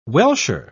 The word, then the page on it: welsher: British English